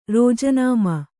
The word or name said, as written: ರಾಜೀನಾಮೆ